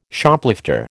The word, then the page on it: Shoplifter: American English